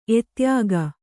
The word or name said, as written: ಎದೆಯುಕ್ಕು